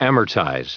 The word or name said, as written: amortize